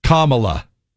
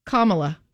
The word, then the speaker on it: Kamala: Here's how I remember it. I just remember comma, like punctuation. – Okay. – Allah. – Kamala. – Kamala. – Yep. Kamala.